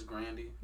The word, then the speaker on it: Grande: It's actually pronounced Grandy, if y'all didn't know. – It's Grandy.